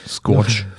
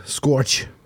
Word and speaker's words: Scourge: Wie wird das ausgesprochen? S-C-O-U-R-G-E. – Habe ich noch nie gesehen, das Wort. Scorch. – Scorch.